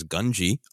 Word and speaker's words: Gungi: His name is pronounced 18 different ways in the arc of the Clone Wars that introduces him us to him. I gravitated and stuck to Gungi for some reason. But since this episode is about respect, he roared his name, and it was translated by the Bad Batch as Gungi. I'm going to assume that that is the correct pronunciation and be respectful of Gungi.